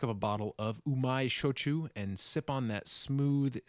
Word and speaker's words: shochu: Shochu.